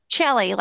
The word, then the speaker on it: Chellie: Chellie, right? – Phillips. – Yeah, now, is it Chellie or Chellie? – Chellie, like a cello.